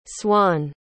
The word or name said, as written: Swann